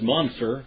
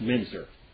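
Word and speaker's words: Müntzer: His name is Munzer, but it should be pronounced Menzer.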